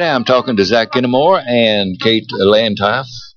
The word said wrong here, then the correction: Kinnaman: Did I pronounce that right? – No, you got close. – Yeah, it's close enough for government work. It's Kinnaman. But Kinnaman.